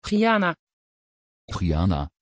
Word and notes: chiana: Dutch

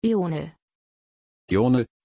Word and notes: ionel: German

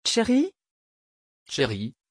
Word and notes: cherry: French

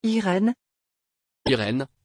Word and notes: irene: French

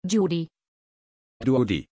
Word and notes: judi: German

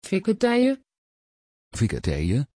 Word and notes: vikentije: Dutch

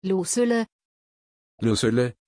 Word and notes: Lucylle: German

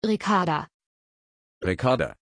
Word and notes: Ricarda: German

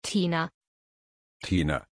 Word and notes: tina: German

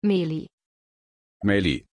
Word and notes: Maili: German

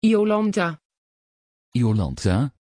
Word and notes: Yolantha: Dutch

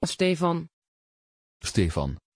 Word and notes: Stefan: Dutch

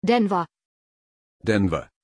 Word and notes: denver: German

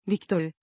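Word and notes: viktorie: Turkish

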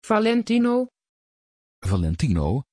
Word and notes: valentino: Dutch

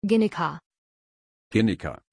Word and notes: Ginika: German

